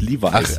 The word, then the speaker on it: Levi's: Nee, Levels, Levi's. – Ach, Levi's, so wird man es eher aussprechen.